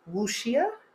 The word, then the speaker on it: wuxia: I loved the Chinese fantasy genre called wuxia. Apparently that's how it's said.